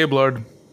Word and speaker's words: Abelard: Ablard, yeah, the Ablard. – How do you spell that last one? – A-B-E-L-A-R-D.